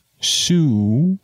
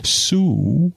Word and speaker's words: Soo: "Sue," he said. It's spelled S-O-O, so I'm trying to replicate that. "Sue," he said, this being the way in which he pronounced her name.